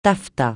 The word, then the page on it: taffetas: francais